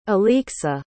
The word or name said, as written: Olexa